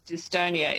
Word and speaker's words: dystonia: Have I pronounced it correctly, dystonia? – You have, dystonia, exactly. – Spelled D-Y-S-T-O-N-I-A.